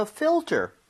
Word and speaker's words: filter: F-I-L-T-E-R. 자, 근데 발음 주의하셔야 되는 게 지금 fill 할 때 L 있죠? – 네. – 그리고 ter 할 때 R. – 와. – Right? L-R. 이 순서로 되어 있기 때문에 네. – 혀하고 입모양이 제대로 들어가야 돼요.